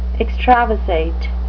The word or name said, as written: extravasate